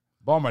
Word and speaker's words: Baltimore: Baltimore. That's the correct way to say it. Baltimore, Maryland. Not not Baltimore.